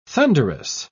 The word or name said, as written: thunderous